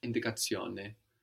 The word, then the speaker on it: indicazione: To make that Italian, we will change the schön to zione, which is spelled Zione, zione. So indication becomes indicazione. – Indicazione. – And these words ending I-O-N-E are feminine words.